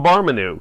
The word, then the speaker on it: Barmanou: I'm going with either Barmanou or Barmanou. It's spelled B-A-R-M-A-N-O-U, but I've also seen it spelled B-A-R-M-A-N-U. – Oh, so let's call him Barmanou, because that sounds more...